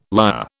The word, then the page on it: lyre: British English